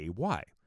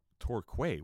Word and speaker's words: Torquay: Okay, Torquay is spelled Torquay. How, as you know, a citizen of the United States of America, how would you pronounce that? Let me ask you. Torquay, right? Wouldn't you go, oh, Torquay, what an interesting name for a town. No, they say it Torquay.